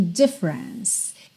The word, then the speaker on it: difference: Atenção à pronúncia dessa palavra, difference. A sílaba tônica é a primeira. E tanto o E do meio quanto o E final não são pronunciados. Então, você vai pensar nela como tendo dois ataques de som. Difference, ok?